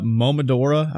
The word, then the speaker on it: Momodora: It's Mo- uh, Momodora, I believe is how you pronounce it.